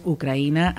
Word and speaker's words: Ucraïna: Val, doncs, Ucraïna, i aleshores, doncs, l'és a dir, és a dir, que el portal doncs, es va avançar i dir, mira, en català és Ucraïna, amb dièresi, i a més, ens ajuda perquè ens facilita la pronúncia, ens diu com l'hem de pronunciar.